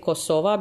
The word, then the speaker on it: Kosova: Or as I say it, Kosova, because that's how I pronounce it in my native Albanian.